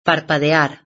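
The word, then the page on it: parpadear: Spanish (Spain)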